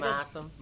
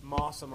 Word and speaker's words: MOSSOM: What's you said MOSSOM? – MOSSOM, like MOSSOM. – Like MOSSOM. – Yeah. For a Pido fan of this band. – No one's ever told me how to say it. – It's MOSSOM is fine.